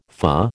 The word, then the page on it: FA: American English